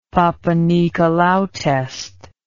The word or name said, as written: Papanicolaou test